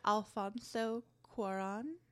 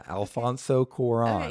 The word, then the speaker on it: Alfonso Cuarón: A-L-F-O-N. – Yeah, it's Alfonso. – Alfonso. Why am I getting laughed at for that? I said it right, didn't I? – Alfonso Cuarón. – Yeah, Alfonso Cuarón. – Okay, yes, perfect.